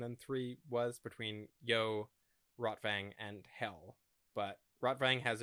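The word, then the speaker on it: Rotwang: And so he takes the map fragments to his old buddy, Rotvang would be the more accurate pronunciation, who is a mad scientist who was in love with Freighter's mother.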